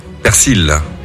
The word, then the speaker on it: persil: Alors, normalement, c'est persil, ok, ouais. D'après le dictionnaire de l'Académie française. Mais le nord et le sud du pays prononcent quand même le L, donc t'as le droit quand même aux deux.